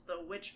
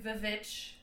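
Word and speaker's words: The VVitch: So he said that one of his favorite is the Witch movie. – Which movie? – The VV. – Yeah. – It's like Vivitch. – Yeah. So it's spelled VVITCH. – Oh. – But it's called The Witch, and I remember seeing the trailer for that one, and I was like, that's a big nope for me.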